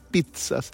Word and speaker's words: pizza: La llevé al parque, fuimos a comprar unas pizzas. Se escribe P-I-Z-Z-A, pero se pronuncia P-I-T-S-A, ¿eh?